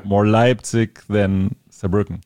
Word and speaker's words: Saarbrücken: And then in Germany, we're playing in Cologne, Hamburg, Berlin, Munich, Saarbrücken... Yeah. Am I saying that right? – Yeah.